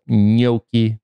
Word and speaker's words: gnocchi: and I pretended I was, but gnocchi, which I think when I'm feeling lazy, I pronounce as gnocchi, but gnocchi is the way to pronounce that.